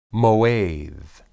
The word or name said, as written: moed